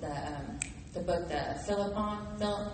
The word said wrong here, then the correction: Philemon: Philemon. No, no, no, no, no, no. no. no. Oh, Philemon. Philemon. Yeah um, Philemon was, that, was this rich guy that Paul had converted, right?